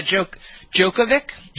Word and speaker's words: Djokovic: And Novak Djokovic, is that how you pronounce his name, the tennis player? What is it? – Yeah, Djokovic.